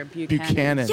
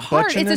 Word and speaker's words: Buchanan: Buchanan. – Yeah, but no one ever pronounces it correctly. I'm like, it was literally a president. Like how? – What do they call it? Butchanan? – It's a street.